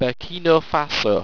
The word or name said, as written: burkino